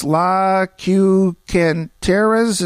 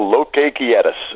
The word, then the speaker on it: Lo Que Quieres: It's La Cucanteras. Is that it? – Well, you're close. It's obviously that you took no Spanish in high school. It's called Lo Que Quieres.